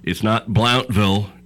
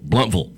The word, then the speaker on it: Blountville: And it's not Blountville, it's Blunt, and it's Vull, Bluntville. – Bluntville, yes. Yeah. – Okay, there you go. B-L-O-U-N-T.